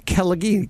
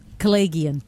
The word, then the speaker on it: Kelegian: Hey, hey, this hour, actress Silva Kelleghi. – Kelleghian.